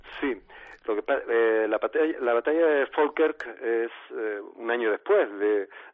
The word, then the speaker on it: Falkirk: William Wallace y sus hombres fueron derrotados en la batalla de, creo que se dice algo así como Falkirk, ¿no? – Falkirk en inglés. Falkirk. Pero bien, se escribe Falkirk, pero es Falkirk.